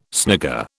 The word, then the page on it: snigger: British English